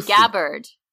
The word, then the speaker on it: Gabbard: Tell us why Gabbard.